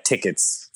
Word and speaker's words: TKTS: And we went up to New York and we went to TKTS to see what we could get tickets for to see on Broadway. Because I had never been to a Broadway show before. – Sure. Or as TKTS pronounces it, tickets.